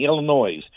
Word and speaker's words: Illinois: If you're not from here, you're saying Illinois.